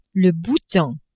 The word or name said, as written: Bhoutan